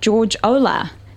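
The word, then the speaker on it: George Olah: So first up, we're talking about George Ola. Is that how I say it? – It is indeed how you say it, yes.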